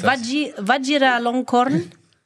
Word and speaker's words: Vajiralongkorn: Wie heißt das? Vajiralongkorn? – Ja, so kannst du es aussprechen.